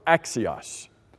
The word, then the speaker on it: Axios: it would be spelled A-X-I-O-S. Five letters. A-X-I-O-S.